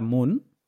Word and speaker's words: Muun: Vas a descargar Moon, que se escribe M-U-U-N, que esa wallet lo hace sencillísimo en todos los aspectos.